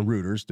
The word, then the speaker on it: Reuters: So one of the first articles to news agencies to break that was Reuters, mm-hmm. or Reuters, depending upon how you pronounce it.